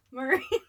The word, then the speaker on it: Murray: Murray. Like, you say it like it's the word furry.